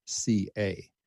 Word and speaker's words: Stoica: And again, if you want to just look her up, the last name is spelled Stoica. So it's Susanna Stoic, but it is spelled O-I.